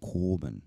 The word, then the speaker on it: korban: Now, this word actually has at the beginning under the letter kof a what they call a kamatz katan, which is why we get the O sound instead of an A, a long A vowel sound. So korban. So the resh has a shavah under it, which means that it's attached to the preceding vowel and as a consonant. So we have two syllables, korban.